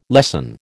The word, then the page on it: lessen: American English